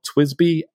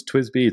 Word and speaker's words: TWSBI: So they're called Twisby Echo. So it's Twisby, it's spelled weird, T-W-S-B-I, and then Echo, E-C-O.